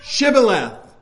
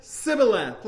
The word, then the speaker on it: Shibboleth: Now, I don't know why it was exactly, but the Ephraimites could not pronounce Shibboleth. And they said it more as Sibboleth, like they couldn't pronounce the SH.